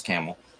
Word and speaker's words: Campbell: It's not like Campbell, but pronounced Camel.